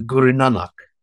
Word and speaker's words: Guru Nanak: And I wondered if we could tease a little bit about, I'm going to pronounce it wrong, I'm sure, with the Guru Nanak. Is that right? – That's it. Yeah, that's it.